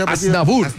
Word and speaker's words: Aznavour: Aznavour, por favor.